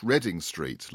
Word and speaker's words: Reading: So this is a card of, I think it's pronounced Reading Street, like Reading the town.